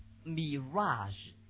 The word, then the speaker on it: mirage: 那么幻想可能有这个词，类似词，这种 fantasy，幻想吧，fancy 会是幻想吗？This one， how to say，这个怎么读？这个要读作 mirage， right？ OK。